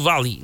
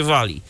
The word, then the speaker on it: Valley: Stardew Valley, nie Wally, tylko właśnie tu Stardew, a potem będzie Valley. No przynajmniej według tego, jak mnie kiedyś w szkole uczyli, że się wymawia angielskie literki.